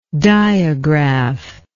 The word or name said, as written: diagraph